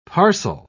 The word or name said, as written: parcel